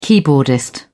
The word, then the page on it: keyboardist: British English